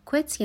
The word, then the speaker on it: quit: Quit یعنی ترک کردن. به تفاوتش با کلمات quiet و quite دقت کنین. تلفظ این کلمه quit هستش. I'm trying to quit.